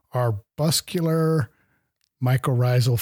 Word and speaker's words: arbuscular mycorrhizal: I wish you the best in your studies at Montana State University and your research on arbuscular mycorrhizal fungi.